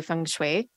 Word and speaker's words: feng shui: And looking at your whole experience, so tying this all to astrology and into feng shui, did I say that right? – Yeah, you did.